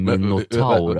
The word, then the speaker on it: Minotaur: Han ser ut som Minotaur alltså. – Vad heter spelet där? – Age of Mythology. – Okay. – Det funkar. – Det var ganska, faktiskt ett okej uttal. Minotaur, det är alltså tjurmänniskor och han är väldigt verkligen lik en tjurmänniska.